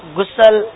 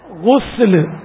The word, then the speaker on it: غسل: استنجا نہیں ہے, اس استنجا ہے, غسل نہیں, غسل اردو میں غسل, عربی میں غسل سین پر پیش ہے.